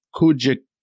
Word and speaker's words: Kujichagulia: It's K-U-J-I-C-H-A-G-U-L-I-A. I'm going to say it's Kujichagulia. Kujichagulia.